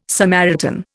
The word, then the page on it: Samaritan: Indian